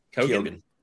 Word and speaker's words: Keoghan: I always say his name wrong, Keoghan.